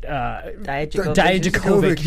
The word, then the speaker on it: Dijakovic: It was very one-sided as far as having Lee, and I can't say his name right. – Dijakovic. – Yes.